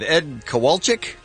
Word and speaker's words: Kowalczyk: The dude from Live, Ed Kowalczyk.